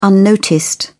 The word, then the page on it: unnoticed: British English